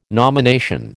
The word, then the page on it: nomination: American English